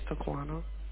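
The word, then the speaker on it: Taquanna: You said my name is Taquana. – Can you spell it for me? – Yes, it's T-A-Q-U-A-N-N-A.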